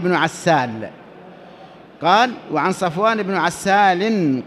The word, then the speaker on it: عسال: وابن عسال كذلك كما تسمعون النطق، عسال عين فسين فلام ألف فلام بعدها.